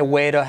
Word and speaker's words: way to: Way to, convierte T como R suave, way to help you out.